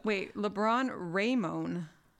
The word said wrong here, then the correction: Raymone: Raymond. James Sr. Emphasis on the Ray. – Yeah. R-A-Y-M-O-N-E. Raymond. James Sr. Yeah, December 30th.